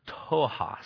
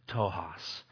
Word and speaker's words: ptochos: Well, the Greek word for poor here in this context is the word tohos. It's actually spelled P-T-O-C-H-O-S, but it's pronounced tohos, which is defined as being destitute or needy.